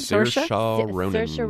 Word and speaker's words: Saoirse: Saoirse Ronan, about her possibly getting a Best Actress nomination.